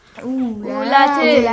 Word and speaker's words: ulati: Ulati. – Ulati. – Again, you gotta skip the wow sound.